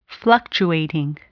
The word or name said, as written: fluctuating